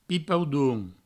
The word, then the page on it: pipaudon: pipaudoun